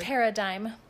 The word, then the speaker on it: paradigm: How do you say that word? Paradigm. Thank you.